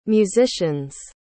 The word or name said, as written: musicians